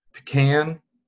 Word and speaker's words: pecan: It's pecan, which. Okay, I'll let that one slide. But and then, of course, pecan, which is the right way to say it.